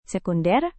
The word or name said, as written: sekunder